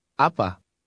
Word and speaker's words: apa: Apa? – Repeat it aloud after him. Always repeat aloud after the speaker. – Apa? Apa? – Did you hear the two different A sounds? The first A is stressed.